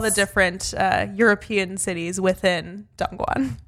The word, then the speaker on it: Dongguan: I think it was north of Shenzhen, in a place called Dongguan, but I might be butchering that name, so I really do apologize.